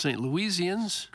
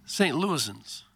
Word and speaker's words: St. Louisans: We don't call ourselves St. Louisians. – Correct. That's a proper pronunciation. – What do you call them? Missourians? – St. Louisans. – All right, well, I think he lives... – There's no Louise in there.